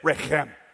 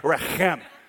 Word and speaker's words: rechem: Everybody say it. Rechem. You got to hit that. Okay, if the person in front of you is not wiping stuff off the back of their head, you didn't say it right.